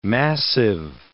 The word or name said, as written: massive